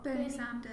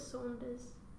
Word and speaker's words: Sanders: Did I pronounce his name? – Triggered. – What? – Bernie Sanders. – Bernie Sanders. – Okay. – Yeah. – I don't know why I thought it was Saunders.